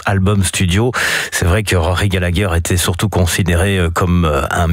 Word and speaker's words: Rory Gallagher: Rory Gallagher, encore que Rory Gallagher, si vous le prononcez comme ça, on va vous regarder de travers, puisque pour les Irlandais, c'est Rory Gallagher.